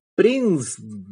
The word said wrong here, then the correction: PRISM: Con M, nos cuesta ahí pronunciarlo. PRISM es la aplicación que estamos utilizando.